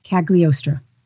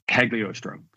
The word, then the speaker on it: Cagliostro: And we see Strange then wake up in the woods and start looking for the lost library of Caligstro, which I'm sure I'm saying wrong, and I even tried to spell it phonetically, folks. – Cagliostro. – Cagliostro, thank you.